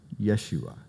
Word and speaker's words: Yeshua: And maybe if you've hung around churches a while, you've heard another word that sounds like teshua, Yeshua. It's the Hebrew pronunciation of the name Jesus.